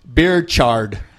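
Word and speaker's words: Birchard: We're going with Rutherford Britcher Hayes. – I'm sorry, I mispronounced it. It's Beerchard.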